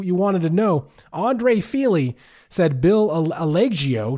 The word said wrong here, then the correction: Algeo: Algio? – Algio